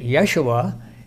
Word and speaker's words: Yeshua: That word, that name of Jesus in Greek, was the Hebrew word or name Joshua, or as they would pronounce it, Yeshua, in the language of the Old Testament books.